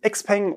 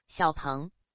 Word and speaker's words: Xiaopeng: Wir starten mit einem Xpeng oder wie die Chinesen aussprechen würden, Xiaopeng oder wie nochmal? Xiaopeng. Naja, jedenfalls Modell P5, erwischt von Katharina in Lübeck.